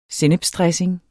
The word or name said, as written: sennepsdressing